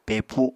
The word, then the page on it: pepu: Apatani